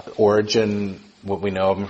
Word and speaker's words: Origen: One of them is a man named Origen, or Oregano, or Oregon, however you want to say his name. It's actually Origen.